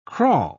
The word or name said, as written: kraal